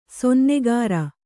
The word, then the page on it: ಸೊನ್ನಗಾರ: sonnagāra